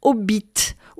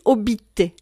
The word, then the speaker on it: obit: appelé obit, ou on dit aussi obite, Que l'on prononce ou non le thé final, cela ne manquera pas d'égayer ces tristes cérémonies que de les appeler les hobbits des morts ou les hobbits.